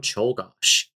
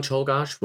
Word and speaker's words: Czolgosz: So, next up, we're going to be talking about the assassination of William McKinley by Leon Czolgosz.